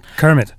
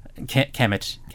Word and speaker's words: Kemet: Yeah, and they also did Kemet, which I believe I'm pronouncing correctly, which is the Egyptian one. – Kermit. – Kemet.